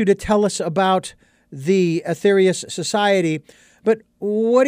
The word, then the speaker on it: Aetherius: I want to ask you about the, obviously, about the society, which, again, I was so intrigued by, the Aetherius, if I'm pronouncing that correctly, the Aetherius Society. – Yeah, that's correct.